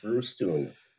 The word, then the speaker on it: Brustuen: It's long U's, Brustuen. Think of stewed prune. It rhymes.